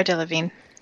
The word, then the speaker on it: Delevingne: How do you say her name? Do you know who that is? – Cara Delevingne. – Is it just Delevingne? Well, what's with all the extra consonants?